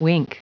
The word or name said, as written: wink